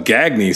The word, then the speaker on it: Gagne: Whatever his last name was, Gagne, G-A-G-N-E, but nope, his name was Gagne. That's how he pronounced it.